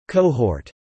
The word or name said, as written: cohort